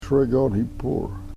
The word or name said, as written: sregonhipur